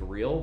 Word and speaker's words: real: So it's spelled R-E-A-L, so it's like real, but it's real. – It's like real, but not real. – Yeah, they pronounce the R's as H's.